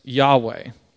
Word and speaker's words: Yahweh: Pronounced Yahweh.